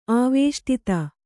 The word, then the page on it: ಆವೇಷ್ಟಿತ: āvēṣṭita